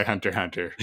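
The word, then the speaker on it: Hunter × Hunter: Maybe just try Hunter Hunter first.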